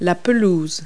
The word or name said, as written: lawn